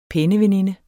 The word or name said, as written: PENNEVENINDERNES